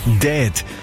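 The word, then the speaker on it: DEHD: Dead schreibt sich, also liest sich, respektive spricht sich aus wie tot.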